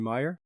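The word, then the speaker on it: Maier: It's very kind of Vivian Myler. I never know how to pronounce her name. – Vivian Meyer. – Maya, thank you.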